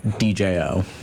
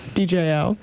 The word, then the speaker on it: Djo: We have End of Beginning by, how do you pronounce it? – DJO. – DJO.